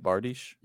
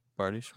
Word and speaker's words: bardiche: Is that how you say it? Bardiche? – Yep, it is. – Bardiche, wow.